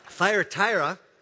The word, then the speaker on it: Thyatira: Revelation chapter 2, where he's speaking to the church in Church of Thyroid. Wow. Thyatira. I said that wrong.